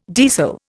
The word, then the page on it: deasil: Indian